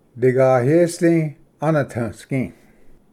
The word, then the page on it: ᏗᎦᏰᏍᏗ ᎠᎾᏛᏍᎩ: digayesdi-anadvsgi